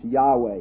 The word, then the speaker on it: Yahweh: YHWH, we think, is pronounced Yahweh.